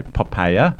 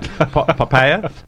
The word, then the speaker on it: Poppaea: or Pompeia. sorry, Poppaea. I should Close pronounce enough. It correctly. Not Pompeia. Poppaea.